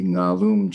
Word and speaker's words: Ngalum: They can pronounce an NG at the beginning of the word, as well as we pronounce an NG at the end of the word, like sing or song, but we don't usually start words with NG, but that was their language and their group, the Ngalum tribe.